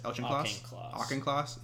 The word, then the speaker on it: Auchincloss: Auchincloss.